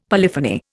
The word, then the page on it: polyphony: Indian